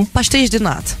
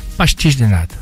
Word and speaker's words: pastéis de nata: Pastéis de nata. – Pastéis de nata.